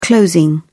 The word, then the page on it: closing: British English